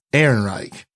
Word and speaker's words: Ehrenreich: Ehrenreich. – Alden Ehrenreich.